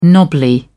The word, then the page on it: knobbly: British English